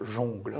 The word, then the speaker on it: jungle: Les puristes prononcent jongle.